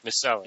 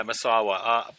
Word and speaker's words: Misawa: Misawa. – Yeah, Misawa.